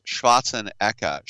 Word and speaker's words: Schwarzenegger: That's why Arnold uh, Schwarzenegger is Schwarzen Eka. Schwarzen is black. Eka, you pronounce the G's like K's. Schwarzen Eka, black plowman.